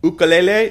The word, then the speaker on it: ukulele: Viola, mandoline, ukulele, that's the correct pronunciation, which I don't use because nobody believes me.